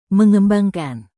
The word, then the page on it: mengembangkan: Indonesian